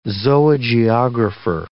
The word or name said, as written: zoogeographer